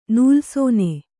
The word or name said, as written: ನೂಲ್ಸೋನೆ